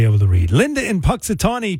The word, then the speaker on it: Punxsutawney: Yeah, but you do realize I pronounced Punxsutawney right this time.